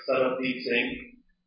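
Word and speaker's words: Sarbdeep Singh: My name is Saradeep Singh, and I will spell it for you. – Thank you. – S-A-R, B as a boy, D as a David, E-E-P, Saradeep Singh, S-I-N-G-H.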